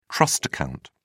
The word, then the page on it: trust account: British English